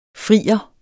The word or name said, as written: FRIERNE